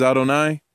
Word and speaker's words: Adonai: The name, the Tetragrammaton, the name Hashem, the name we pronounce is Adonai.